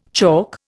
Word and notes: chalk: Indian

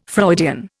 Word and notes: Freudian: Indian

